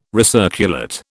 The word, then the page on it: recirculate: British English